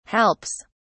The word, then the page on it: helps: British English